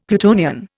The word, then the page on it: Plutonian: Indian